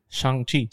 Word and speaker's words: Shang-Chi: Is it Shang-Chi? – Shang-Chi.